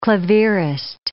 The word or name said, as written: clavierist